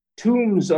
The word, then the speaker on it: Toombs: That's spelled T-O-O-M-B-S, Toombs.